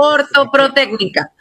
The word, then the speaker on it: ortoprotécnica: Bueno, conversamos acá de un tema importantísimo, importantísimo, y el trabajo que vienen haciendo, en este caso, ortoprotoica. Creo que lo dije bien, ¿no? – Ortoprotécnica.